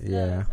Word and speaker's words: Lowe's: They were like, "That's not how you say it. It's Lowe's."